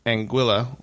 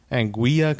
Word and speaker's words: Anguilla: We went to first we landed in St. Martin, but then we took a boat over to Anguilla, which a lot of people mispronounce. – What do they usually say? – Anguilla, because there's two L's and they think it's Spanish, or Antigua, which is a completely different island.